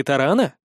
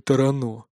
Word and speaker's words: Тарано: «Как ты сказал? Тарано?» — не поверил я. «А не Тарана?» «Нет. Если в роду имеется хотя бы три поколения одаренных, то его приравнивают к аристократическому и разрешают ставить ударение на последний слог».